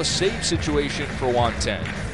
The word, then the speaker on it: Then: Juan Ten pronounced Ten, spelled T-H-E-N.